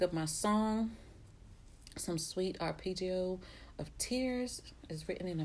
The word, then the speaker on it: arpeggio: I think it's arpeggio. – It's arpeggio. Arpeggio.